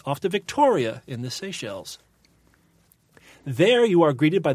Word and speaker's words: Seychelles: Oh, the Seychelles. – Whoa. Seychelles. – S-E-Y-C-H-E-L-L-E-S, right? – Right.